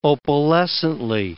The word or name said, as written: opalescently